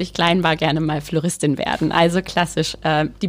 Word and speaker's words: Flowristin: Nun habe ich dich ja vorstellen dürfen als Floristin. Ich hoffe, das ist richtig ausgesprochen. Ja.